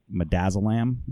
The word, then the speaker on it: midazolam: A case about the state of Oklahoma's use of a particular drug, midazolam. Is that right? Is that the right pronunciation? – Correct. It is.